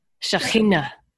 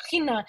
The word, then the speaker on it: Shekhinah: Is there any other final thoughts you have to talk about Shekinah? I'm not saying that right. – Say, Can you do the ch? – The ch. Shekhinah. – Shekhinah.